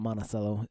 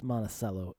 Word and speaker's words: Monticello: Here's what I like about Monticello, is that it's pronounced Monticello and not Monticello.